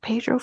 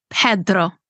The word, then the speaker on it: Pedro: And can I say, Pedro for me used to be... – No, sorry. It's Pedro.